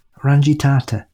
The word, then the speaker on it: Rangitata: This one summer, Uncle David took me up to the This one summer, Uncle David took me up to the Rangitata River to go rabbit hunting. I'll do that again. – I mean, I don't know how it's pronounced either, so. – Rangitata.